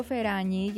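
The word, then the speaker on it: Ferragni: Τη λέω Φεράνι γιατί στα ιταλικά είναι το W με νι, είναι νι. Μην το κοιτάτε που λέμε Φεράνι εδώ, γιατί το Φεράνι μας φαίνεται λίγο με προφορούλα περίεργη, αλλά είναι Φεράνι.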